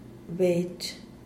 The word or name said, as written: bech